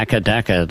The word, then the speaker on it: AC/DC: Die Aussprache von AC/DC in Australien, die geht so. – Yeah, akadaka, they say. – Die Leute sagen akadaka, ohne Querstrich.